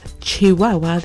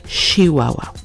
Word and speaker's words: Chihuahua: Not everyone, so don't come at me about this, but many people from Chihuahua pronounce the ch as sh, so instead of Chihuahua, they say Chihuahua.